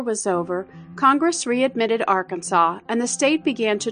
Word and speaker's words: Arkansas: A controversy had raged over the correct way to pronounce the state's name, and this was settled by adoption of an official pronunciation, Arkansas.